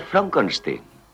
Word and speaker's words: Frankenstein: Frankenstein. ¿Me toma el pelo? No, se pronuncia Frankenstein. ¿Dice usted también Frederick? No, Frederick. ¿Y por qué no es Frederick Frankenstein?